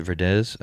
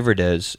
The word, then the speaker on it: Verdes: Is it Verdes? Okay, because the first time we did this, I pronounced his name wrong. So Tai Verdes, and the album is called TV.